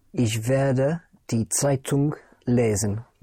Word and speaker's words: Zeitung: Die Zeitung would be the newspaper. Spelled Z or Z, E-I-T-U-N-G. Die Zeitung. And the N-G in German, unlike in English, the N-G is pronounced. Zeitung. The G comes out, die Zeitung.